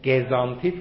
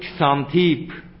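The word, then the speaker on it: کسانتیپ: اسم زن سقراط چی بود؟ گزانتیف. نه، اگر اسمش گزانتیف بود ده شاهی نمیارزید. اسمش بود کسانتیف. آره گزانتیف که تو میگی تلفظ فرانسویشه، نباید اونجور بگی.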